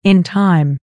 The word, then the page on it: in time: American English